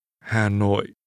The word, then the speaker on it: Hà Nội: Hà Nội. Nội. Hà. Hà Nội. Hà Nội. – This city's name is pronounced using low falling and low falling broken tones.